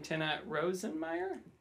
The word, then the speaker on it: Rosenmeier: We've got Tinne, and I'm going to try and say your last name, Tinne Rosenmeier.